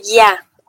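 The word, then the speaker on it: ya (я): Por ejemplo, en ruso, yo se pronuncia ya.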